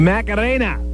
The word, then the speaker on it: Macarena: You think you can play the margarita for me? – The margarita? – Yeah. – Man, are we white? – Yeah, I can't say it right. – The Macarena.